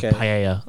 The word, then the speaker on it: paella: Well, you meant to not even say the L's, it's Y's. It says like paella. That's kind of how it's said.